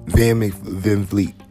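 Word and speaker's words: VanVleet: Kyle Lowry and LaVette. You know, Van Vliet. Sorry, saying his name wrong.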